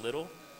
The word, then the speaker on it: Liddell: It's spelled Liddell, but it's pronounced Little.